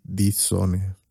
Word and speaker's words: die Sonne: Der Mond y die Sonne.